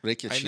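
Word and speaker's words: Rakić: Rakic, I know the dude's a destroyer, too, so it's going to be an interesting fight.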